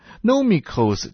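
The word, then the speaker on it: Nomikos (νομικός): 在这里提到律师，律师这个词儿希腊文读作 Nomikos 这个字可以指的是他是一位犹太的律法专家，可是针对散居在外的人， Nonikos 也可以指的是他们是精通罗马法的学者。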